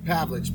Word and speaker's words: Pavlich: Pavlich, P-A-V-L-I-C-H.